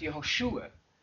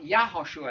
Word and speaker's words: Yehoshua: By the way, the pronunciation, perhaps Yehoshua, Yehoshua, the stress on the shu, if you're going to use that, not Yahoshua, I think, but in the Hebrew, Yehoshua or Yeshua, and even Hosea, it turns up in different forms in the Old Testament.